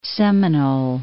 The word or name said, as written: Seminole, Lake